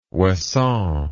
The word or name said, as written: ouessant